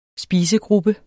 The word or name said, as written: spisegruppe